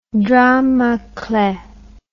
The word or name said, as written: drame a clef